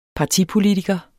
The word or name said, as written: partipolitiker